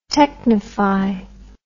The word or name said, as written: technify